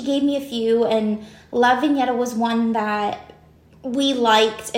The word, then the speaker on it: La Vigneta: The way we spell it is Sicilian. – Okay, so. and it's pronounced La Vigneta. – La Vigneta.